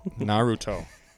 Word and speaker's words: Naruto: Naruto. – Okay. So I had it spelled right. So Naruto, Dexter, and The Boys.